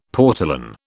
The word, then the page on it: portolan: British English